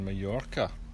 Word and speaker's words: Mallorca: Here we are in Mallorca. Mallorca. – Mallorca. – Spelled M-A-L-L-O-R-C-A, but pronounced with a Y. Or a J. Mallorca. So we're in Pollença. Spelled P-O-L-L-E-N-C-A.